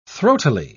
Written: throatily

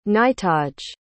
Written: knightage

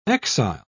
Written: exile

